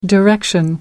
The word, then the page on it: direction: American English